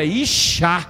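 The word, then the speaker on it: Ixá (אשה): A mulher não, tem uma letra a mais, é Ixá.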